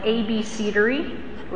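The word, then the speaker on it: abecedary: Okay, so the inscription is known as and this is how it's pronounced in English. It's called an ABCdery, which is just like it sounds, an ABC, and that just means an alphabetic inscription.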